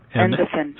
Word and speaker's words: Endefen: E-N-D-E-F-E-N. – Okay. – Endofen.